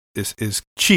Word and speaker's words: Qi: Because, again, if you look at, like, you know, English now, Q-I is Chi. It's what it's... How it's pronounced. You know, it's because it's the Qi wireless standard.